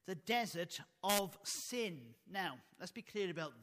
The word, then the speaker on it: Tsin: This desert of Sin here had nothing at all to do with it being a morally bad place, because sin comes from the Hebrew word spelt T-S-I-N, pronounced sin.